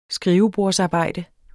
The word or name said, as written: skrivebordsarbejde